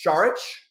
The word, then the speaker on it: Šarić: I believe the correct pronunciation, of Dario's name is Saric, like it's like an H in the after the S.